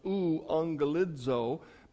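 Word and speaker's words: euangelizo (εὐαγγελίζω): In Greek, it would be pronounced euangelizo, but E-U, and then the word for an angel, if you will, or a messenger.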